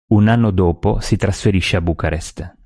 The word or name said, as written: Bucarest